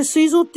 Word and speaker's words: 膵臓: あ、膵臓でしたっけうん、そのあたりかなと思ってたんですけど、それ膵臓って読むんですよね。